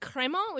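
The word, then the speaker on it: Crémant: The word Cremant, which is spelled C-R-E-M-A-N-T.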